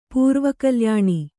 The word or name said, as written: ಪೂರ್ವಕಲ್ಯಾಣಿ